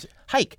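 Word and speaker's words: Huyck: How do you pronounce Hike. – It? Hike. Willard yes.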